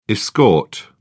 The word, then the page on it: escort: British English